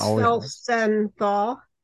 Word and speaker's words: Felsenthal: It's Felsenthal.